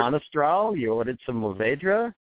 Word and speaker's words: Mourvèdre: Mourvèdre, like moo cow, moo, or Mourvèdre.